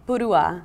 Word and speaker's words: Purua: Purua. – Purua. – Yeah, Purua. – It's the accent is at the end.